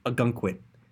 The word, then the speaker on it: Ogunquit: Is that how you pronounce it? They pronounce it strangely in the show. Ogunquit.